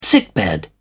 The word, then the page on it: sickbed: British English